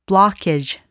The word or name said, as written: blockage